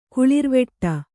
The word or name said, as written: ಕುಳಿರ್ವೆಟ್ಟ